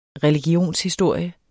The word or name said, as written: religionshistorie